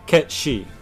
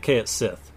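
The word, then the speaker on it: Cait Sith: I say Ketshi. And the reason I say it that way is because the Irish cat fairies... the word she is fairy in that sort of Irish Celtic lore. And, yes. – And so it's Ketshi. But... For a long time, I said it K at Sith.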